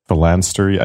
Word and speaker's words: phalanstery: Isn't it phalansteries? – Yeah, like monasteries, but... – Phalanstery? I think it's phalanstery.